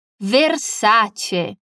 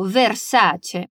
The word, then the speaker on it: Versace: Primeira coisa é, na verdade, um ponto com relação à pronúncia da marca de grifes de luxo. Versace. È italiana, mi raccomando, Versace.